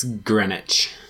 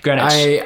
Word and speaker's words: Greenwich: I think it's Greenwich. – I'm sorry, did I pronounce the W? Greenwich.